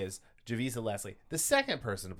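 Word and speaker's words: Javicia: To be clear, Kane is no longer Batwoman, who is now played by Javisa Leslie. I think that's how you say her name. – Yeah.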